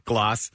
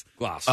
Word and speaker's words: glace: It's called the the glass. Glace. – Something like that. – How do you say it, Frenchman? – Glace. – Glace.